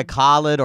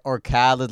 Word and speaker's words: Khaled: To say Khaled. So, like, Khaled? I go by Khaled or Khaled. Like, both those are fine. – Yeah. – But it's like, I've had people be like, I want to pronounce it right. And they're like, try to say Khaled. And then it just sounds so fucked up when they try. Then I'm like, just call me Khaled.